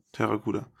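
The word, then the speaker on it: Pteracuda: Aber das P, the P is silent, wie Richtig. bei Psychology. Genau. Deswegen Terracuda.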